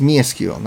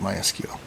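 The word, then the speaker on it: MySQL: So anyway, it's Me SQL, not My SQL, even though that's how almost everybody pronounces it, except for the founders.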